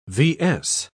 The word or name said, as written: vs